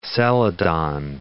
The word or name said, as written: celadon